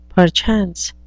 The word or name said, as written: perchance